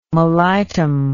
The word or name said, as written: mellitum